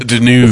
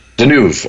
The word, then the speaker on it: Deneuve: is that how you pronounce her last name? – Deneuve. – The French. Deneuve, okay.